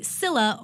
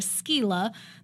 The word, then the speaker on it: Scylla: But Greece also has the Scylla or Scylla, depending on if you're pronouncing it the old Grecian way or, you know, the Scylla. Scylla. It's spelled S-C-Y-L-L-A for us who just are curious.